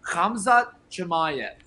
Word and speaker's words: Khamzat Chimaev: So the pronunciation is Khamzat Chimaev.